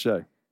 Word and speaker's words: Skjei: S-K-J-E-I. – It is pronounced Shea.